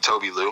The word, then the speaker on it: Tobi Lou: Peep Tobi Lou. Uh, it's spelled T O B I, like Toby, and then space, and then Lou, like L O U.